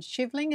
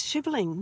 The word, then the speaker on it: Shivling: But in terms of achievements, I think it kind of started when you climbed Shivling. Is that the right pronunciation? – Yes, it is. Shivling.